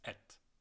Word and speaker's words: être: And as it's followed by a consonant, we don't even pronounce the RE, so it's et, right?